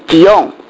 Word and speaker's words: 锡安: 锡安希伯来话念成吉翁，它的意思就是凸出来，高出来。